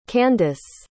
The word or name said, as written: candyce